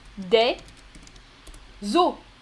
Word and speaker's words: os: Tu ne prononces pas le S final, mais tu fais la liaison avec l'article. Un nos, tu fais la liaison aussi hein, avec le N, un nos, des zo. Au pluriel, tu prononces juste O. O, des os.